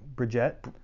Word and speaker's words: Brigette: Like I was thinking of one that's, I think you pronounce it Bridget. – Bridget, okay. – Yeah, and so it's like B-R-I-G-E-T-T-E or something like that.